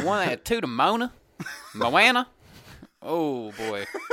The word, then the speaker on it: Moana: It's Moana. – Moana.